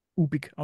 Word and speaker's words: Ubik: Ubik ausgesprochen, UBIK.